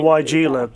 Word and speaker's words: Weigela: And the other one was, I don't know how to pronounce this, is my sister-in-law used to call it Weigela. It's Weigelia. – Weigelia, yep.